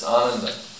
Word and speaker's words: Ananda: It's not Ananda, it's Ananda. Long first day. Sachit Ananda Vigraha, not Sachit Ananda. Right? Indian voice? Sachit Ananda. That long mark is there over the A for a reason.